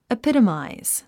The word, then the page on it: epitomize: ipítəmàiz; エピトマイズ